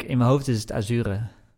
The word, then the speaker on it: Azure: ja ik, het Azure. Ja, het is de Engelse uitspraak.